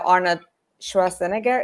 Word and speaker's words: Arnold Schwarzenegger: I've heard that they wanted to have Arnold Schwarzenegger. Is that how you pronounce English? – Yep. Mm-hmm.